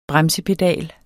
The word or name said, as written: BREMSEPEDALEN